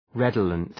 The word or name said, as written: redolent